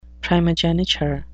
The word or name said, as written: primogeniture